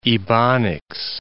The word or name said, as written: Ebonics